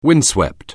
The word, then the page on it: windswept: anglais